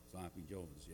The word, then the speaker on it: Sloppy Joe's: With an emphasis on the Joe, Sloppy Joe's, yeah."